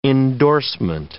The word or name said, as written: indorsement